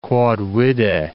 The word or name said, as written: quod vide